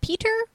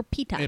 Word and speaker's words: Peeta: Is it Peeta or Peeta? – Peeta. Yeah, Peeta. I just said it wrong. – So, and now is it P-I-T-A, like the food? – No, P-E-E-T-A.